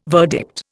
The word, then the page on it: verdict: Indian